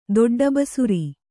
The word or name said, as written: ದೊಡ್ಡಬಸುರಿ